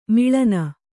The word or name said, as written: ಮೇಲನ